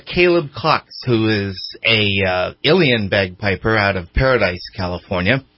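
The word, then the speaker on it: uilleann: It's pronounced Ilian. It's U-I-L-L-E-A-N-N.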